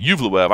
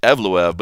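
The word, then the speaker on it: Evloev: Talking about those rookie reports, Mosar Yuvluev, I think is the right way to say it. Yuvluev. I was saying Evluev, but it's Yuvluev.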